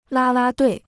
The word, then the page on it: 啦啦队: lā lā duì